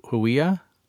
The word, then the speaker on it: Huia: Is it Hu- Huia? Oh yeah, yeah. – That's it. It's a, a New Zealand bird, um, and it's extinct.